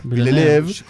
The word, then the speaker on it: Villeneuve: Villeneuve. Como el automovilista Jacques Villeneuve.